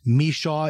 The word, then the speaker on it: Michaud: His name is Stephen, S-T-E-P-H-E-N. The last name is spelled M-I-C-H-A-U-D. Michaud is a pronunciation. That's what my producer tells me. Stephen, do I have that pronunciation right? – You're pretty close. It's more like Michaud, but I answer to everything, so that's fine. – Michaud, okay. Right. But it's spelled M-I-C-H-A-U-D.